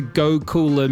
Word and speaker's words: Gokula: Big shout out to Go Cooler Music, which is spelt G-O-K-U-L-A, the fine sponsor of this here music show, Dirtbag Radio.